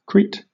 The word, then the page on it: Crete: Southern England; /ˈkɹiːt/